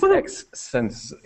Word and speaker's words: X-Sense: You said it correctly, well done. That's this X-Sense, yeah, or XS if you can't really say that, but not TempSense. They're very clear about that on their webpage.